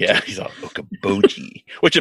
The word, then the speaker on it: Okoboji: It's Okaboji.